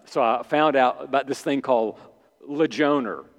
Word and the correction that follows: Ligonier: which is Ligonier. I mispronounced it.